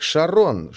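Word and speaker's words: Charon: Интересен тот факт, что на английском языке это имя произносится как Шарон, что созвучно с именем жены первооткрывателя Шарлин.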